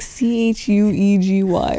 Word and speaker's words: cheugy: It was like C H U E G Y or something. Chuegy. Anyways, it's pronounced chuegy.